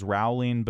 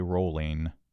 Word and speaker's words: Rowling: For a long time, I thought this was Rowling, but then someone told me it rhymes with bowling, so I'm going with J.K. Rowling.